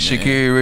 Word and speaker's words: Sha'Carri: Shakira. – All right, Stephen. I just, man, you got to be on your toes, Richardson, man. Sha'Carri Richardson, man.